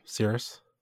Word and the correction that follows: Sirius: Nope, Sirius.